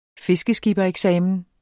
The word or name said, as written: fiskeskippereksamen